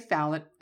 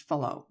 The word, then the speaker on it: Fallot: So, blue babies, the actual disease is tetralogy of, I'm going to say phallet, and I'll guarantee you it's pronounced fallot.